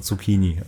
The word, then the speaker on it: Zucchini: Zutscheneini. Kennst du Lux an Wunder? Korrekte Aussprache? – Ja. – Gibt's auch Zutschen-Eini. – Zutschen-Eini, ja, stimmt. – Aubergine-Eini und Zutschen-Eini sind die Romeo und Julias der Obsten. – Guck mal bei YouTube nach Luxan Wunder, Wundertütenfabrik. – Korrekte Aussprache. – Und dann macht die korrekte Aussprache Zucchini.